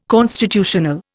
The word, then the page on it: constitutional: Indian